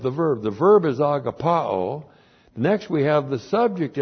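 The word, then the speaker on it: Agapao (ἀγαπάω): God the Father is the subject of the verb here, and the verb is agapao. A-G-A-P-A long O. Agapao.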